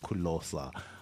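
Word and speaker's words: Ku Lo Sa: Kulosa, man. It's Closer, pronounced Kulosa.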